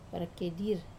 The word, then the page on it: vara-kiai-dir: Wamesa